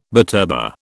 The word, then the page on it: butterbur: British English